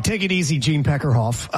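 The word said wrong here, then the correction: Deckerhoff: Am I saying his name right? – Gene Dekeroff. – Oh, Dekeroff.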